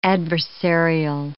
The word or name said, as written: adversarial